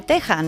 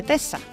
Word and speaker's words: Texas: Os cuento, la letra X no siempre se, se pronuncia como KS, ¿no? Como en extraño o exclusivo. Pues hay palabras en las que no representa ese sonido, que es el suyo actual, sino el de la J, como en México, que se escribe con X, y Texas, que se dice el estado de Texas, no de Tesa.